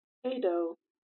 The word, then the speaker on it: ADD-0: My name is ADD-0, pronounced Ado.